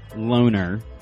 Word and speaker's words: Loughner: Jared Loughner. That's L-O-U-G-H-N-E-R. Even sometimes referred to or pronounced as Loner.